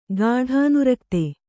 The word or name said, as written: ಗಾಢಾನುರಕ್ತ